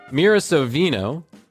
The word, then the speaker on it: Mira Sorvino: Mira Sorvino, who we talked about before on this show. – You just said her name correctly this time.